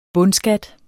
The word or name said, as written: bundskat